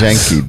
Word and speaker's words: Yankee: Janky, Daddy Janky. That's how they say it.